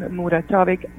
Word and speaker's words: Muratovic: Yeah, it's a little bit of, it's a, it's it's a Serbian name, so it has all these lots of consonants, and it's kind of silent letters. It's T-I-J-A-N-A, and then last name Muratovic, M-U-R-A-T-O-V-I-C.